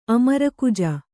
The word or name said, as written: ಅಮರಕುಜ